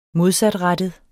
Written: modsatrettet

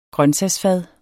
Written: grøntsagsfad

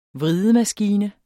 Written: vridemaskine

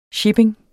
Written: shipping

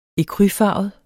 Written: ecrufarvet

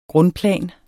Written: grundplan

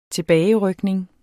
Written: tilbagerykning

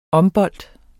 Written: OMBOLDE

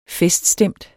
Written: feststemt

